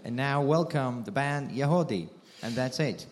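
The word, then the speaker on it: Yagody: YAG. O-D-Y, but it's pronounced Yahode.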